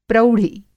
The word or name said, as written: प्रौढ़ि